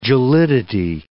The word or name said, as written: gelidity